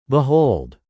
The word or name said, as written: behold